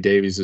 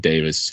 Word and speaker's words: Davies: Davies is trying to, and we're three of us are going to pronounce his last name Davies tonight, not Davis, for the people in over across the pond.